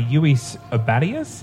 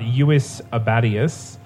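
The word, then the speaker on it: Lluis Abadias: It's an image drawn by Yuis Abadius. Now, he gave me phonetic instruction on how to say his name. I'm sure I still butchered it. But Yuis Abadius... Twitter at Yuis Abadius, spelled LLUISABADIAS.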